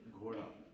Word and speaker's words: ghora: G-H-O-R-A. – Don't you dare go give it English. – Gora.